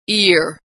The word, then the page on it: ear: American English